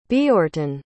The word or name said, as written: Beorhttun